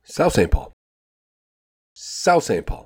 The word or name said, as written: South St Paul